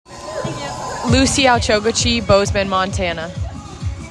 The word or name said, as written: Lucy Al-Chokhachy